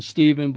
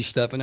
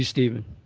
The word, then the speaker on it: Stephen: Well, I like the pronunciation Stephen, but it could be Stephen. I mean, it's either or, tomato, tomato. But for today, it'll be Stephen.